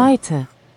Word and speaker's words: Meute: Maita. – Como? – Maita. Maita. – Maita, Maita. – É, Maita, a Moita. – Moita. Maita se escreve, gente, se escreve M-E-U-T-E.